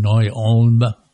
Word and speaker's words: Neu-Ulm: Well, after that, I was assigned to a mechanized infantry battalion stationed in Neu-Ulm, Germany. I hope I said that right. – Yes, you did.